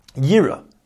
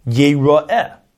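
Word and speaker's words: Yeroeh (יראה): Shenema, because it says in the pasuk Yira. Now, but we pronounce it as we said Yeroeh.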